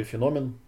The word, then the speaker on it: феномен: Вот здесь вот, собственно, и говорится, да, что в 1879 году французский специалист электротерапии Габриэль Вигуру впервые описал феномен, который, или феномен, правильно говорить, да, который известен сегодня как электродермальный ответ.